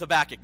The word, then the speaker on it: Habakkuk: There's the biggest discrepancy that I've come to so far as I've been studying this is, do you pronounce it Habakkuk or Habakkuk? And I'm going with Habakkuk.